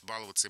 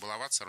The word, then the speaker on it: баловаться: Большим орфоэпическим словарем 2012 года он был признан допустимым как младшая норма. Большой словарь ударения русского языка, который вышел в прошлом году, пишет: баловаться и баловаться — равноправные варианты. – Да что ж такое? – То есть, ну, ударение баловаться можно, движется, да, движется в сторону нормативного.